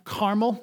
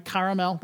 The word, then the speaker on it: Carmel: You may remember it from your Sunday school lessons on Mount Carmel, not Mount Caramel, that'd be sticky. Mount Carmel, although if you're American, that's how you say it, I suppose.